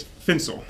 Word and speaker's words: Fincel: It's Fincel.